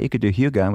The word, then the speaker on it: hygge: It's spelled H-Y-G-E-E. But it's this ancient old art of cozy. And the Danish called it hygge. – Oh, I know what you're talking about, but I would have never pronounced it that way. – It looks like hygge. – Yes. – Or hygge. Hygge. It's hygge.